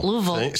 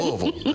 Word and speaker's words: Louisville: So is also from Louisville. – Louisville. See, she says it correctly. Louisville. That's it.